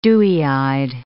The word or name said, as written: dewy-eyed